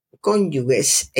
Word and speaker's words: cónyuge: Cónyuge se pronuncia cónyuge con J, se escribe con G, no con GU. Si se escribiera con GU, podríamos decir cónyuges pero se, escribe con J, cónyuge. Y se escribe con G, J-U-G, No G-U, insisto, cónyuge con G se pronuncia J-U-G, como si se escribiera con J.